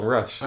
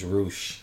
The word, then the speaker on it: Rush: You actually got his name right, because you usually call him Rush. – I know, because it is Rush. – It's Roosh.